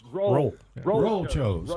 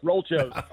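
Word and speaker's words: Roll Chos: Roll Chose. – Okay. You're so close. Roll Chose. – Okay. – Yeah, what are Chose? That's the last syllable of Broncos. We spell Broncos with an H.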